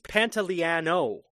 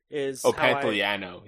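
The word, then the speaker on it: Pantoliano: But he's Pantaleano. – Pantaleano.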